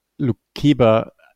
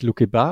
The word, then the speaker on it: Lukeba: Und zweitens fand es gerade lustig, ganz am Anfang, ähm, äh, Julian hat ähm, Lukeba ein bisschen anders ausgesprochen, nämlich Lukeba. Und da ist mir zum ersten Mal aufgefallen, dass der Dreiviertel Lukebakio heißt. – Ja. – Daher war die Aussprache auch.